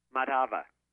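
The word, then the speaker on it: Madhava: Matava, that's another good company.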